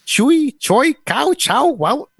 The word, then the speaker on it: Choix: C-H-O-I-X, right? – Yeah. – How do you say that? – That's chicks. – No. That's choice. – Choy. It's choy.